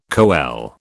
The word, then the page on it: coele: British English